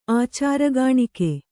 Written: ಆಚಾರಗಾಣಿಕೆ

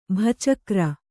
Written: ಭಚಕ್ರ

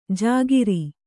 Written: ಝಕಾರ

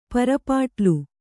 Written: ಪರಿಪಾಟಲು

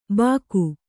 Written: ಬಾಕು

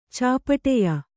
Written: ಚಾಪಟೆಯ

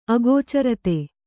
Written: ಅಗೋಚರತೆ

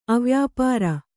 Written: ಅವ್ಯಾಪಾರ

